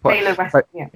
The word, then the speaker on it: Taylor Wessing: is it Taylor Wessing? Is that how you pronounce it? – Yeah. Taylor Wessing.